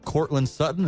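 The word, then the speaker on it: Courtland Sutton: So all they have is like Cortland Sutton.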